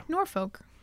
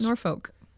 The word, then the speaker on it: Norfolk: L is not to be pronounced. – So is the L in Norfolk not supposed to be pronounced? – Norfolk.